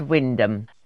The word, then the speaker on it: Wymondham: So it's written Y Mondham, W-Y, M for mother, O, N for nowhere, D for delta, H-A-M. So it looks like it's Wymondham, but it's pronounced Wyndham.